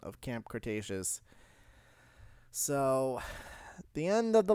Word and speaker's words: Cretaceous: Cretaceous. Yes. Sorry, I had to make sure. – You've done this. – Devin was giving me shit for saying it wrong.